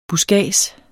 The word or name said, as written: buskads